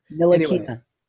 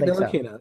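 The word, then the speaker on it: Ntilikina: Nilakina. – I heard the N is silent. I read that somewhere. Frank Nilakina, so.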